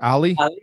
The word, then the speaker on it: Ali: How do you say his name? Ali or Ali? – Ali.